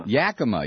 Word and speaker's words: Yakima: Yakima.